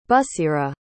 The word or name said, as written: busera